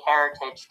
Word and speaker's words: Heritage: Okay, so last weekend was the RBC Heritage Tournament.